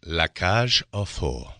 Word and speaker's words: La Cage aux Folles: And with me, I have a lead actor in, I'm going to let you pronounce correctly, La Cage aux Folles. – La Cage aux Folles. I'm practicing my French. – You're doing well.